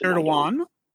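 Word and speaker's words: Erdoğan: And it's how how uh, Erdogan, whose name I always get wrong. Erdogan. Erdogan, who uh, initially, because the Chinese are literally putting the Uyghurs, I think I'm pronouncing that right.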